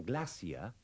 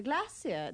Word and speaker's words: glacier: In these sentences and dialogues, don't pronounce the R in glacier and the RE in shore.